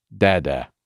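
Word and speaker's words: dada (დედა): And mother in Georgian is pronounced dada.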